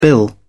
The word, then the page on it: bill: British English